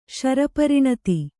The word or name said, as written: ಶರಪರಿಣತಿ